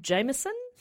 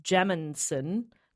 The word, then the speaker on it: Jameson: Connor added a note, says I've searched it before and always pronounces it like Jaminson, but it's Jameson.